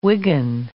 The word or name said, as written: Wigan